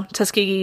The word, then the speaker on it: Tuskegee: And this was at the Tuskegee Institute, now Tuskegee University in Alabama. And I had to learn how to pronounce Tuskegee for this episode, because my brain was like, it's Tuskegee. It's not. – It is not, no.